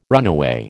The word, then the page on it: runaway: American English